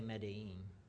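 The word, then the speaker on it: Medellín: But it's spelled like Medillo, but you say Medellin.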